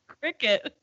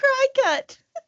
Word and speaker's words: Cricut: What is it? Cricut? – Cricket. It's a Cricut printer. – I've never known. I thought it was a cry cut.